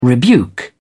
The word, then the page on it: rebuke: British English